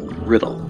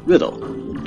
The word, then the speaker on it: Riddle: That's right, Riddle. Not Ridley, not Ridla, not Rydell, not Riddell. Riddle, just like it's spelled.